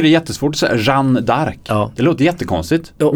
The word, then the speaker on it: Jeanne d'Arc: Det ska vara Jeanne d'Arc. – Mm. – Och jag säger Jean d'Arc.